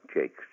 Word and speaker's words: Jacques: When I go to America, people fondly call me Mr. Jack Hughes, or Jack Wes, or even people who think they can speak French say, hey, it's pronounced Jock. Down by the Mexican border, it sounds rather nice. It's Mita Haqueth. It's an old French name, Jakes, J-A-C-Q-U-E-S.